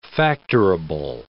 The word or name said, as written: factorable